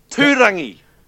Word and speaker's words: Tūrangi: Can you spell it for me? – T U R A N G I. – No. Tūrangi. – Is that it? – Tūrangi. Yep.